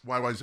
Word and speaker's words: YYZ: And then they said, well, let's throw in the Morse code, you know, rhythm by the way, they pronounce it YYZ. – YYZ, that's correct. That's what The radio, Z sounds too much like other letters, so they have to change the pronunciation so nobody mistakes it. – No, all Canadians said when they say Z, there's no Z in Canada. It's Z. That's how Canadians say Z.